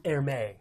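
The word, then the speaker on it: Hermès: Dresden Hermes, or Hermé. Let's call it Hermé. – Okay. – Hermé, I believe, is the French pronunciation.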